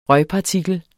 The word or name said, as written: røgpartikel